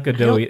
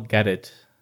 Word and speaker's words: Gadot: It's not Gadot. It's Gal Gadot.